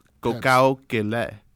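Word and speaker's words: Gokao Kele (可口可乐): Well, Coca-Cola realized this, and they did a little bit of research, and they finally settled on the Chinese pronunciation as Gokao Kele, which means happiness in the mouth.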